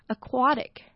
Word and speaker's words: aquatic: 那 aquatic 呢它是形容词， A 是发的音，重音在 QUA 的地方， aquatic， aquatic， aquatic。